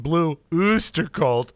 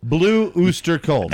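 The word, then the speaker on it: Blue Öyster Cult: And remember, with the umlaut over the O, it's best pronounced Blue Oyster Cult. – Blue Oyster Cult.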